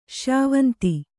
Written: ಶ್ಯಾವಂತಿ

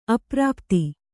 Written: ಅಪ್ರಾಪ್ತ

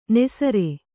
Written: ನಿಸ್ಸಾರ

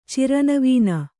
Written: ಚಿರನವೀನ

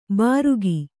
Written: ಬಾರುಗಿ

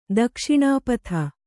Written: ದಕ್ಷಿಣಪತಿ

